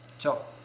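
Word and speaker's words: cho (저): la prononciation, la particule, le démonstratif, c'est cho. Mm. Cho. Et pour le lieu, ça devient chogi.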